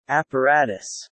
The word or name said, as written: apparatus